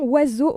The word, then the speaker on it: oiseaux: C'est le plus long mot dont on ne prononce aucun des sons associés principalement aux lettres qui le constituent. On n'entend pas O, I, S, E, A, U, X. Dès lors, pourquoi ne pas l'écrire Oiseau, W-A-Z-O.